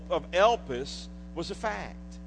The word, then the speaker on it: elpis: And that little word hope in the New Testament we pronounce is elpis, E-L-P-I-S.